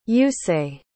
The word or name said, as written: yusei